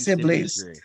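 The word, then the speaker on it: Sibblies: Is it Sibley's Drury? Jackie Sibley's? – Yes. Yes, Sibley's.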